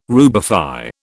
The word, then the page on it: rubify: British English